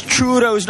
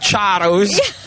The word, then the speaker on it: churros: I was now going to ask, is it charro or churros? – Okay, now say his name also. Who's our... – Sorry, it over here, reminding us that it's churros, not charros.